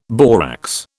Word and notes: borax: British English

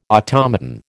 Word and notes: automaton: American English

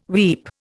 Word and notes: weep: Indian

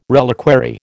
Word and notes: reliquary: American English

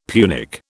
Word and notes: Punic: British English

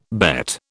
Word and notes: bet: British English

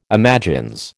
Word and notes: imagines: American English

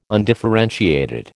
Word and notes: undifferentiated: American English

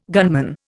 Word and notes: gunman: Indian

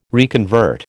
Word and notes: reconvert: American English

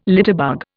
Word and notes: litterbug: Indian

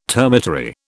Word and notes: termitary: British English

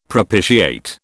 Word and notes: propitiate: British English

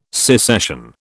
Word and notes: secession: British English

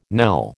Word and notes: knell: American English

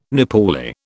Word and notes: Nepali: British English

